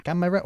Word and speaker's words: camera: Camera o... – Camera. – Camera. – Camera. – Eso es, camera. – Camera. – Dos sílabas, ¿eh? Solo.